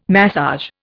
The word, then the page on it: massage: Indian